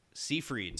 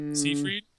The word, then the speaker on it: Seyfried: The girl from Seyfried. – Ted. Seyfried.